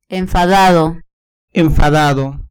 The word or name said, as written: enfadado